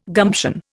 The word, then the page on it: gumption: Indian